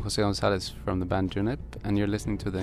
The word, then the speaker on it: Junip: So in Swedish, we have the month, June, which is pronounced juni. And in English, you have June. So it's very easy to just do Junip when you're talking Swedish and Junip when you're talking English.